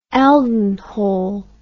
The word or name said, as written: Elden Hole